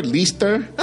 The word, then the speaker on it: Lester: Say Lexter. – Okay. Lester. They say everything but Lester.